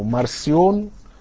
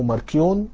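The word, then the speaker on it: Marcion: الراجل ده كان بيؤمن ان للكون الهين، ما هو النطق المختلف على حسب حرف السي، ففي ناس بينطقوا السي زي في كات او سي زي في كلمة سيلندر، فبينطقوها يا اما مارسيون او ماركيون مش تفرق يعني.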